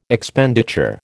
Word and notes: Expenditure: American English

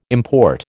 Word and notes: import: American English

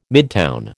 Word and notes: midtown: American English

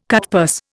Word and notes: cutpurse: Indian

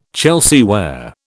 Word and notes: Chelsea ware: British English

